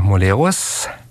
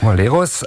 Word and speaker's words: Molerus: haben wir heute Petra Molerus. Habe ich das jetzt richtig ausgesprochen? – Perfekt, richtig gut. – Gut, Molerus, Ausstattungsleiterin am Theater Ulm im Studio zu Gast.